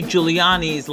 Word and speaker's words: Giuliani: One thing I learned from this segment is that Rudy Giuliani's last name is pronounced Giuliani, not fucking Giuliani.